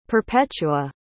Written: perpetua

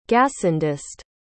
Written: gassendist

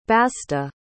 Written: basta

